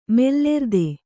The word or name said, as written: ಮೂಲಾರ್ಥ